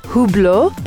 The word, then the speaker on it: Hublot: Moschino is written M-O-S-C-H-I-N-O. So people might say Moschino, Moschino, but it's actually Moschino. Hublot. Hublot, it's written H-U-B-L-O-T. So people might mistake it by saying Hublot, Hublot, Hublot, but it's actually Hublot.